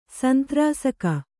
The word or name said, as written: ಸಂತ್ರಾಸಕ